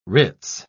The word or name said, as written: ritz